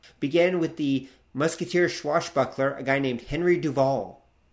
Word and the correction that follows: Henri Duval: I think it's pronounced Henri Duval.